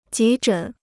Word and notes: 急诊: jí zhěn

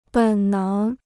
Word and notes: 本能: běn néng

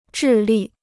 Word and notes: 智力: zhì lì

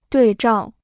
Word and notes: 对照: duì zhào

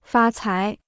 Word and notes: 发财: fā cái

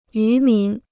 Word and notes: 渔民: yú mín

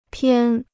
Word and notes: 偏: piān

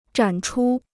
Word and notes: 展出: zhǎn chū

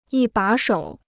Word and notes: 一把手: yī bǎ shǒu

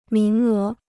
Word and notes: 名额: míng é

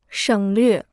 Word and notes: 省略: shěng llüè

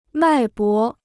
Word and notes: 脉搏: mài bó

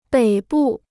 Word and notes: 北部: běi bù

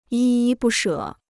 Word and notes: 依依不舍: yī yī bù shě

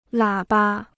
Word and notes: 喇叭: lǎ ba

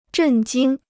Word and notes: 震惊: zhèn jīng

